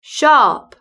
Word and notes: sharp: British English

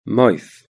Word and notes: mouth: British English

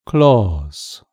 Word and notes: claws: British English

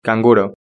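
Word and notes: canguro: Spanish (Spain)

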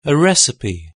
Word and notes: a recipe: British English

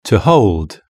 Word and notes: to hold: British English

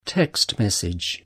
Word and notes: text message: British English

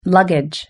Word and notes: luggage: British English

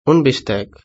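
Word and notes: un bistec: Spanish (Spain)

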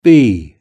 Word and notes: bee: British English

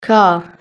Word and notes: car: British English

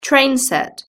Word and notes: train set: British English

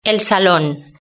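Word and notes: el salon: European Spanish

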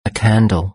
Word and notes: a candle: British English

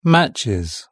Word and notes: matches: British English

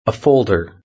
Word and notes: a folder: British English

